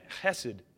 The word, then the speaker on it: chesed: In the Hebrew, chesed is how you say it. You got to spit a little bit. Chesed.